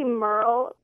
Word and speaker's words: Murrell: Is it Murrell or Murrell? How is it said? – It's actually Merle, but...